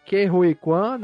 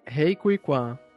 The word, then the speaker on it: Ke Huy Quan: É, o Ke Huy Quan, né, retornou agora aos holofotes. – Parabéns pela pronúncia. Repete aí. – Rei Kui Kwan.